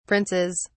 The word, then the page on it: princes: British English